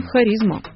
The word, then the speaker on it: харизма: Но вот что важно в смысле ударения. Мы-то заимствовали это слово не напрямую из древнегреческого, а через французское посредство. Там это слово выглядит как «харизм» с ударением на «и». Вот вам и харизма.